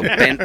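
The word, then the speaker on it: Beng: Instagram, Bent Washburn, B-E-N-G. B-E-N-G, you don't pronounce the G. That's how I get my street name, Silent G. And so, Bent Washburn on Instagram or Facebook.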